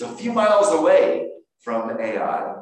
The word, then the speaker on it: Ai: And there's another story after that, the city of Ai, or Ai, as you would pronounce it,